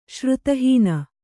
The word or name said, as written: ಶ್ರುತಿಹೀನ